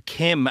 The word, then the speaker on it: Keem: To the beautiful Keem Bay, or Kim as the locals pronounce it, Cormac.